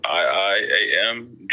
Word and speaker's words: IIAMJUNE: If you guys are interested in listening, my artist name is spelled IIAMJUNE.